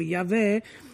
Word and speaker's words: Yahweh: il tetragramma impronunciabile di Dio, quello che noi non dovremmo pronunciare, ma che gli esegeti pronunciano Yahweh.